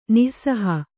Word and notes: ನಿಃಸಹ: nih saha

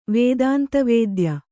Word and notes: ವೇದಾಂತವೇದ್ಯ: vēdānta vēdya

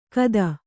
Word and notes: ಕದ: kada

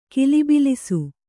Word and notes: ಗಲಬಲಿಸು: galabalisu

